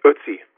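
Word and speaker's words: Ötzi: Do you pronounce his name Otzi? – It's Otzi. – Otzi. Okay.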